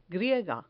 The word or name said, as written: griega